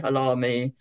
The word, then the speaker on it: Halahmy: And because he put the silent H in, we say Halahmy. Nobody else spells it that way. So if you are from the Halahmy family, once the internet appeared, we have unique names on the internet.